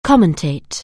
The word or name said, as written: commentate